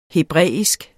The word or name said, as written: hebraisk